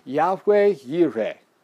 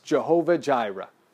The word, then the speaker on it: Yahweh Yireh: He calls it Yahweh Yireh. Or you've heard it probably pronounced or mispronounced, Jehovah Jireh.